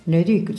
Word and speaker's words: Leduc: ¿Lo he dicho bien? – Leduc, sí.